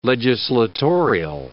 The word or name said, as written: legislatorial